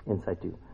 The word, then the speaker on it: in situ: That's how you say it? In situ? Yeah, in situ.